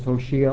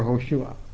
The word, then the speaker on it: Hoshea: Now that could be pronounced as Hoshea or Hoshua, but he changed his name to Joshua.